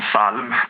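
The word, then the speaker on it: psalm: Ja, men till exempel så har vi ord som salm till exempel, eller ljud, där vi då stavar LJ på ljud och salmen med P, men vi uttalar inte P, det är liksom stumt.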